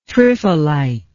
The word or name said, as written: triphylite